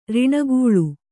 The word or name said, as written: ಋಣಗೂಳು